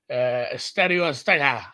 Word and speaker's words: Estadio Azteca: The Bay to Mexico City for Estadio Azteca.